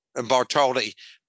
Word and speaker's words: Bartholdi: Bartholdi, Picasso and Matisse were strategic copiers.